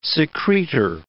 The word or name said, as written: secretor